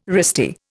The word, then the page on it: wristy: Indian